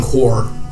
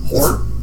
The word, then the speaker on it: Hoar: Actually started following Hor, the god of vengeance. – How do you spell that? – H-O-A-R. – Okay. – Yeah. Totally like the frost.